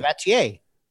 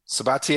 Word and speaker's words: Sabatier: Hey, pronounce how you said his name, his last name. – Sabatier. – Sabatier is correct.